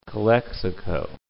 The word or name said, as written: Calexico